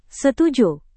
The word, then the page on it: setuju: Indonesian